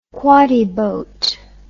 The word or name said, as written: quoddy boat